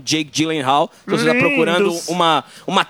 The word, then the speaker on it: Gyllenhaal: tem o Jake Gyllenhaal, que eu acho que é essa a pronúncia, né? – Isso.